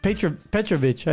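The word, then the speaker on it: Petrović: Drazen Petrovic. – Oh, yeah. Petrovic. I know that's how you say his last name.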